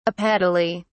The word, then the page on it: apetaly: British English